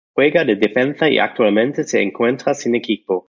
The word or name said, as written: actualmente